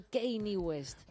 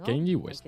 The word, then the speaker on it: Kanye West: Pero adelante, cuéntame, ¿qué ha pasado con el bueno de Kanye West? – Pues, mira, las pronuncia muy bien, las pronuncia súper bien. – Amigo, Kanye West.